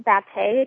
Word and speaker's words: Batte: It's Kyra Batte. That's K-Y-R-A, B as in boy, A-T-T-E .wix.